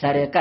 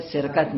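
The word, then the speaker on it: سرقة: مثل سرقت که به اشتباه میگه از سرقه هست، تلفظ درستش تو کتاب لغت مراجعه بکنید، تو روایات بلا اینا چی هست؟ از سرقه هست. ما دیگه میگیم اون سخت دیگه، ولی سرقت نیست.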